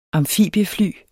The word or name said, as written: amfibiefly